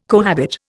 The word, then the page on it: cohabit: Indian